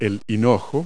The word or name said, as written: fennel